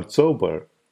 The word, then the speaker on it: Chaubal: Devyani or Chobar, which would be the more authentic pronunciation of her name in Marathi, was a gossip columnist who wrote for film magazine Star and Style and a women's magazine called Eve's Weekly.